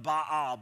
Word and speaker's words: Baal: Now in Hebrew, it's pronounced Baal the Baal. But in English, we just call it Baal, and that's fine.